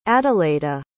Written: adalheida

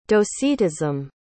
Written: docetism

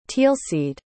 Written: teelseed

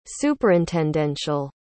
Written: superintendential

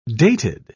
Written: dated

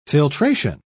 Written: filtration

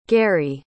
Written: garry

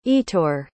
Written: etor

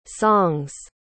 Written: song's

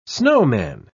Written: snowman

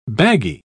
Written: baggy

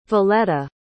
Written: voletta

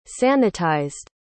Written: sanitized

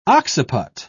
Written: occiput

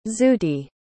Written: zooty

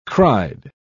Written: cried

